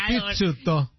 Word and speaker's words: Pizzuto: Se pronuncia como pizza. Pizzuto.